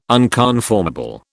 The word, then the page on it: unconformable: British English